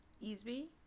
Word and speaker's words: Easby: Easby? – Yeah. – E-S-B-Y? – E-A-S-B-Y. Like, easy with a B.